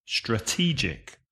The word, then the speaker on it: strategic: The adjective is strategic. Also 3 syllables, but be careful. The stress changes. Strategic. Uh-huh.